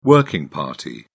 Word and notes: working party: British English

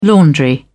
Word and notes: laundry: British English

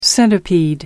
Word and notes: centipede: American English

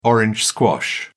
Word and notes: orange squash: British English

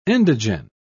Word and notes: endogen: British English

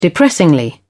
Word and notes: depressingly: British English

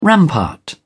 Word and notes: rampart: British English

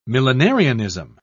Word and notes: millenarianism: British English

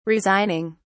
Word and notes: resigning: British English

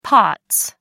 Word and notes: pots: American English

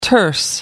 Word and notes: terse: American English

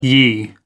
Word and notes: ye: American English

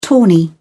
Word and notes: tawny: British English

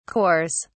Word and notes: cores: British English

